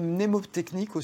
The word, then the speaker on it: mnémotechnique: Est-ce qu'il y a des moyens mnémotechniques aussi Je dis bien mnémotechnique, je ne sais pas si on le prononce, le N, mais il existe.